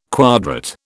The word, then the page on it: quadrat: British English